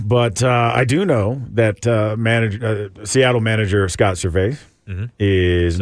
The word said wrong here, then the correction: Servais: Service. – Is it Service? – It's Service. – I've always said Servais. – I know. It's spelled Servais, but... – It's Service. – It's Scott Service.